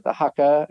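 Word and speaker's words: haka: And so coming into the end, having that, I didn't know they did the Hakka, I think is how you say it.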